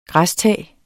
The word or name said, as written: græstag